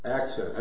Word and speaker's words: accent: We saw assent, altered meaning through changed emphasis. Accent, I said that wrong, right?